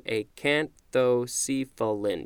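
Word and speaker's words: acanthocephalan: Acanthocephian. A-C-A-N-T-H-O-C-E-P-H-A. Oh, that's an L. L-A-N. Acanthocephalin. And I'm just going to check the pronunciation. Acanthocephalin. Yep.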